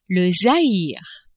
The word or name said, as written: Zaire